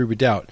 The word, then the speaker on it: redoubt: That's with a silent B, R-E-D-O-U-B-T.